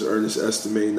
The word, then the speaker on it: Ernest Estimé: This is Ernest Estimé, not the other Ernest.